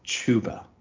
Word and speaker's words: Chuba: It's Chuba. – Okay. – Chuba. It's the long O sound with the U. If we were looking at grammar, it would have the line over top of it.